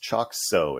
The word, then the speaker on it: Choxo: The company is pronounced Choxo. It sounds like there's an S in there, although it says XO.